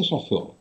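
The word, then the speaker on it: шофёр: очень образованный такой, э, работяга, скорее всего, да, но вот если вы хотите производить более изысканное впечатление, то говорите шофер.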